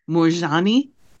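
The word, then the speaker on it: Moorjani: M-O-O-R-J-A-N. M-O-O-R-J-A-N-I. – Okay. How do you say it? – Morjani. – Oh, Morjani.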